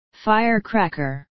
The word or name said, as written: firecracker